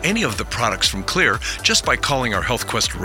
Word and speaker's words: Xlear: Also, you can find all of Clear's xylitol-containing products, that's the Clear nasal and dental products, because they've got your bases covered. Oh, by the way, Clear as in clear is spelled X-L-E-A-R.